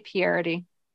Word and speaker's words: Pieridae: I pronounce it Pieridae, but I've been learning that everything I say is incorrect. So how do you guys pronounce that? – I say Pieridae. – Oh. I too say Pieridae. – Okay, good. Well, then I got that one right.